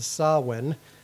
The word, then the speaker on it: Samhain: Samhain. It's spelled Samhain. Just different language, different rules.